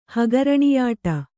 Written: ಹಗರಣಿಯಾಟ

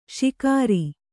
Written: ಶಿಖರಿ

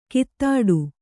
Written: ಕೈತಟ್ಟು

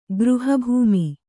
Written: ಗೃಹಭೂಮಿ

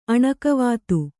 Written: ಅಣಕವಾತು

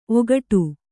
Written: ಓಗೊಡು

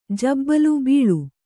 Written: ಬೈಲ

